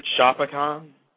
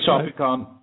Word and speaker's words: Shopicon: Is it Shopicon? – It is Shopicon. Some people say Shop Icon. I mean, whatever works, I guess. But it's Shopicon.